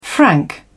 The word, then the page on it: frank: British English